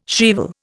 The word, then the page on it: shrieval: Indian